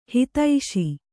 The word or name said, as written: ಹತ್ತಿಸು